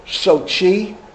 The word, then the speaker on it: Sochi: Sochi.